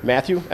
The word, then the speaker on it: Matthew: Sure, Matthew DeRose. – Just spell it and your title. – Sure, Matthew, M-A-T-T-H-E-W, DeRose, D-E, capital R-O-S-E, and I'm the paramedic supervisor.